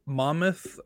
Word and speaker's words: Monmouth: Uh, I'm going to be watching Penn State play uh, Monmouth.